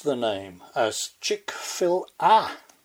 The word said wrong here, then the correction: Chick-fil-A: Short A. A mighty host of listeners emailed in to tell me that it's pronounced Chick-fil-A. As in, fillet.